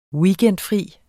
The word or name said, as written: weekendfri